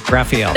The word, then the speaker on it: Raphael: And the Ninja Turtle is pronounced Raphael.